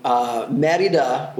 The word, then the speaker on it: Mérida: Yeah, so Mérida, which I say things now with the Spanish pronunciation because I've been here long enough where I've been hearing it enough.